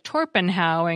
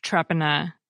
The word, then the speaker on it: Torpenhow: The surname that looks like Featherstone Haw can be pronounced to sound like Fanshawe, for example, while the place that looks like Torpenhow in Cumbria is pronounced Trepena.